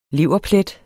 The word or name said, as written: leverplet